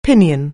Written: pinion

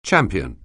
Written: champion

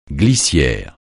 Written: glissière

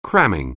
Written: cram

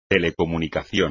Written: telecomunicación

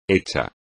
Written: hecho

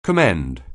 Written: commend

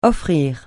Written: offrir

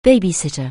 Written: baby-sitter

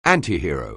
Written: antihero

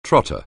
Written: trotter

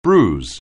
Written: bruise

